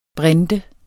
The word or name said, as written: brinte